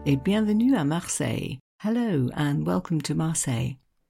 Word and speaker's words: Marseille: It's no coincidence, you know, that Marseille rhymes with soleil,